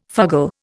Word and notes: fugle: Indian

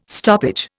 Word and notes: stoppage: Indian

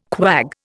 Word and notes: quag: Indian

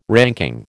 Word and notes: ranking: American English

